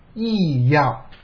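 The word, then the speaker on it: 意乐: 那么第三个呢，就是心，这个心呢，后来的一些翻译，后期的翻译叫意要，这个专门的词叫意要，这个意要是这个心意的意啊，这个要呢是一个多音字，也就是快乐的那个乐字，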